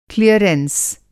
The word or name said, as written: क्लियरेंस